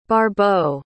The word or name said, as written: barbeau